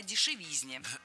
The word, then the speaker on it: дешевизне: Ведь в слове «дешевизна» ударение всегда падает на третий слог. Поэтому должно быть «кстати о дешевизне».